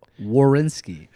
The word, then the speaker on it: Werenski: Werenski. – You still said it with the R first. – Werenski. – Werenski.